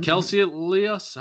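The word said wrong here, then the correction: Kaecilius: How do you fucking pronounce that name? Caecilius. Caecilius, that's it.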